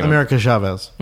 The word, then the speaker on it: America Chavez: America Chavez. – American Chavez.